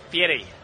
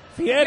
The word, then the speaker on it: Fieri: Fieri. – Fieri.